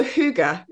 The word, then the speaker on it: hygge: And I just like, I'm so jealous of the Scandinavians because there's also hygge.